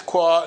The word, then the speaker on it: qua: Qua. Almost like the island in Hawaii.